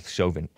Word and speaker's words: Chauvin: And the cop's name is Derek Chauvin, as in Western Chauvinist. I'm kidding. But that's how it's spelled, Chauvin. C-H-A-U-V-I-N is supposedly this officer's, I say former, as if he's already fired, his name.